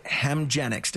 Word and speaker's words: Hemgenix: Canada's just delivered its first publicly funded Hemgenix. Did I say that right? – Yes.